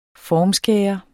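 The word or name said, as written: FORMSKÆRE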